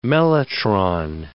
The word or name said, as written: mellotron